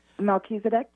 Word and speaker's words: Melchizedek: Melchizedek, yes.